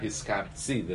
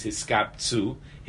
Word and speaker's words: Hiskaptzu: Hiskapt, by the way, it's not Hiskaptzi. The Hasidim say the correct pronunciation is Hiskaptzu.